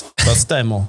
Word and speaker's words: Vastaamo: But a company, how do you say it? Vastaamo? Is that how you say it? – I mean, you're more Finnish than I am. – It's Vastaamo. – Vastaamo. – There you go.